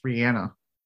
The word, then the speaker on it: Rihanna: Also, we also say Rihanna's name wrong. It's Rihanna.